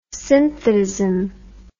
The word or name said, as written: synthetism